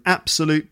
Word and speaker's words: absolute: So the stress on the word absolute is on the first syllable, so an absolute pleasure.